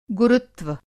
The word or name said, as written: गुरुत्व